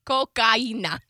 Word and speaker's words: cocaína: I love that you always mispronounce the his coquina. – Cocaína. – Coquina. – Nope. – Cocaína. – Okay, that's enough. – All right, okay. Yes, is right.